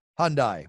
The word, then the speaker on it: Hyundai: Hyundai.